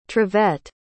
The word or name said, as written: trevet